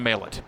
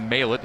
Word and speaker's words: Maillet: One ball, one strike from the lefty Brady Maillet. The 1-1 from Maillet. I said Maillet a moment ago. Brady Maillet is the correct pronunciation.